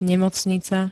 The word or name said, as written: nemocnica